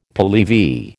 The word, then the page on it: Pehlevi: American English